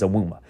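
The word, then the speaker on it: Evbuomwan: I was told that that name was pronounced Awuma, and I watched it on the broadcast, and it is pronounced Awuma. And I don't know how they got Awuma out of it, but I'm going to get it right from now on. It is Awuma.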